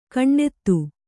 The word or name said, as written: ಗುಣ್ಣಿತ್ತು